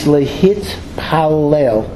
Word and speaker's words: lehitpalel: There are a number of words for prayer, but the most common word for prayer, or to pray as a verb, is lehitpalel.